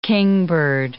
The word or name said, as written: kingbird